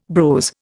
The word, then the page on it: brose: Indian